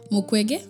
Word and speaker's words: Mukwege: Mukwege. – Mukwege, okay.